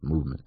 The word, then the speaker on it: MVMT: Spring is right around the corner, and MVMT, pronounced movement, is dropping all new sunglasses to get you ready for the warmer weather.